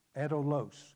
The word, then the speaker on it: adolos: The Greek word there is adolos, if I'm pronouncing that right. A-D-O-L-O-S.